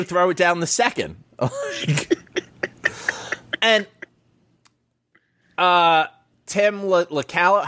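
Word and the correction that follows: Locastro: how do you say his name? – LaCastro.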